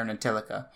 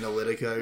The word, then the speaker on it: Ntilikina: I found out that the T is silent, but I'm pronouncing it as Ntilikina because that's how the fuck I'm going to pronounce it until I figure it out.